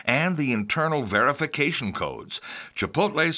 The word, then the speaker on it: Chipotle: Chipotle, and yes, that's how to pronounce it, I had to look it up,